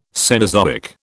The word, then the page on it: Cenozoic: British English